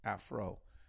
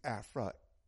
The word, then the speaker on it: Afri: And also, too, understand the word, they spell it out and pronounce it Afro. Afro, an Afro is a hairstyle. We should use Afra, A-F-R-I, Afra. Just like it's spelled, when you spell Africa, okay?